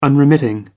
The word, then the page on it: unremitting: American English